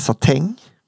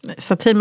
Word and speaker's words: satin: Eller satäng? – Satin, men allt som slutar på ien ska uttalas satäng. – Ja. – Som är liksom... – Men heter det satin eller satäng? Clementäng, mandaräng.